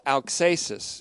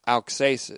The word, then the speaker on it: auxesis: This method is called auxesis, as we've seen before, A-U-X, long E. It's a kind of a Greek word, but it's auxesis. It is a Greek word, auxesis.